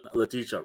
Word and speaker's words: Letitia: Is it Lakeisha? That's my bad on the pronunciation. – Yeah, that's fine. – Is that right? – I think it's Letitia. I don't know. – Letitia Wright. That's right.